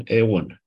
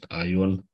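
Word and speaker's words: Aion (αἰών): După pronunția din neogreacă, unde ai se pronunță E, îl avem Eon.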